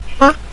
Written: ba5

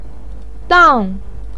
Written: dang4

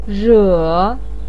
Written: re3

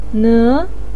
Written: ne2

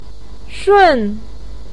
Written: shun4

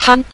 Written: han5

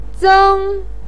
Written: zeng1

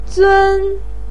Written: zun1

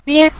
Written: nie5

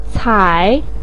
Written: cai3

